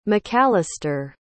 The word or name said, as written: macalister